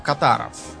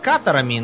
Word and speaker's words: катары: А, важное замечание, их э, правильно вообще называть катарами, но вот в русском языке я сплошь и рядом натыкаюсь на поставленное ударение катар. Раз уж я привык, пусть, пусть так и будет.